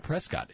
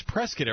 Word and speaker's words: Prescott: And I now know how to pronounce it's not Prescott. It's Prescott, Arizona, because I went camping there earlier this summer, Ron, for a couple of nights, and it was gorgeous.